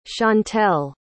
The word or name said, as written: chauntel